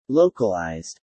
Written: localised

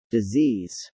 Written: disease

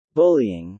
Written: bullying

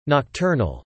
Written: nocturnal

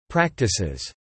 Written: practices

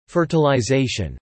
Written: fertilization

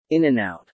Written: In-n-Out

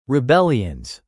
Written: rebellions

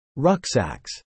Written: rucksacks

